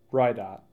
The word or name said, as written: Ridott